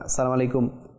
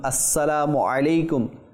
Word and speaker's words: আসসালামু আলাইকুম: অনেকে সঠিকভাবে উচ্চারণ করে না, সালাম আলাইকুম সালাম আলাইকুম তো অনেকভাবে বলে, কিন্তু সঠিক মখরাজ উচ্চারণ না হলে এটা দোয়া কীভাবে হবে, এটা নেকি কীভাবে হবে? আপনি সালাম দিবেন কিন্তু এর মাধ্যমে নেকি অর্জিত হবে না। তো সঠিক উচ্চারণ আসসালামু আলাইকুম ওয়া রাহমাতুল্লাহি ওয়া বারাকাতুহু।